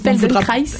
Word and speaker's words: Feldenkrais: Ça peut être au niveau de l'ostéopathie en méthode Poyer, ça peut être Feldenkrais. Alors, je suis désolée pour la prononciation. – Feldenkrais.